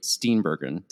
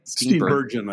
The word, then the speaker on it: Steenburgen: played by Victor Garber and Mary Steenburgen. Did I say that right? – Steenburgen, I think. – Steenburgen. I always struggle with her last name, but she's an amazing actress.